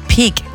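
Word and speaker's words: Pig: Übrigens, absoluter Favorit ist nach wie vor der APA oder ehemalige APA Austrian Presseagentur-Chef Clemens Pick. Der heißt wirklich wie Pick, wie das Schwein. Also schreibt man auch so wie Pick.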